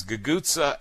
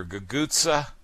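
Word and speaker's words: cucuzza: Guguzza and tromboncino doing beautifully. David, of course, would be proud of your guguzza. We hear about guguzza every now and then on this show.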